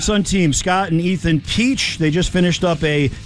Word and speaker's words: Pesch: Last name is spelled P-E-S-C-H, but it's pronounced Peach.